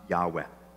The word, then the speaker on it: Yahweh: It's pronounced Yahweh.